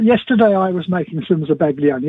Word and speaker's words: zabaglione: You're going to talk food science, and he's talking about edible, delightful foams and Zabergonian. – That would be Zabaglione. – Yep. You there? – Oh. – Zabaglione.